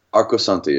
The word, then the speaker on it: Arcosanti: Are you an how do you pronounce it again? Arcosanti, is it called? – Arcosanti, exactly. It's an Italian word.